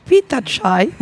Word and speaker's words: Pitakaj: Pitachai. Ça s'écrit Pitakaj, mais j'ai appris à prononcer Pitachai.